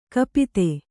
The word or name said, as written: ಕಪಿತೆ